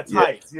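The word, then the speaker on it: Theis: Who's number 27? – Daniel Tice. – Yeah, Tice, yes.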